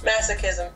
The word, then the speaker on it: masochism: and masio masochism. Am I saying that right? Masochism. – Thank you, masochism.